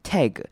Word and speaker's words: tag: 首先看到第一个重点呢是 tag，第一句的 tag 这个字，这个字是也是很多我听到台湾人会常念错的，他们都会有一点台式的念法念成 tag，但是其实这个字标准念法应该是念短母音 a 的声音，念 tag，把嘴巴张大一点 a 的声音 tag myself。